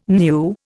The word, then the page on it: new: Indian